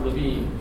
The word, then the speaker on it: Lewin: And it comes from Kurt, it's Red Lewin, but it's actually pronounced Levine.